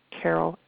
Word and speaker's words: Carol: It's meetme.so, so meetme.s as in Sam, O as in ostrich, / Carol A. Anderson. My name is spelled C-A-R-O-L, and then A is my middle initial, no dot though, Anderson, A-N-D-E-R-S-O-N.